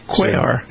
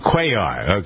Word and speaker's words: Cuellar: Here's the radio host Jack Riccardi talking to Congressman Henry, is it Cuellar? Is that how you pronounce his name? – Cuellar. – Cuellar, okay.